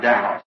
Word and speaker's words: Daugh: Not really in his name, he's a German, D-A-U-G-H, and I also pronounce it Dow. – Ngoku ngenene igameni lakhe ungumjamani D-A-U-G-H kwaye nje ndilibiza Dow.